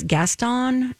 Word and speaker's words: Gaston: We've got Joshua Henry is Gaston.